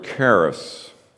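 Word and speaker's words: charis (χάρις): Grace is the word charis. Sounds like the word Christ, charis, looks like it, C-H-A-R-I-S, Christ, C-H-R-I-S-T in English, charis, Christ, homonyms.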